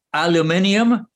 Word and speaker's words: aluminium: The supply chain here was the absolutely vital material of aluminium, as it's pronounced in one part of the Atlantic,